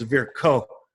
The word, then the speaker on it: Virchow: It's spelled V-I-R-C-H-O-W. We were taught it in school. We've all been calling it Verschall. but go Google Scholar, how do you say Verschall in German? Because it's a German physiologist. and it's varicose.